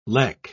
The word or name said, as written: lek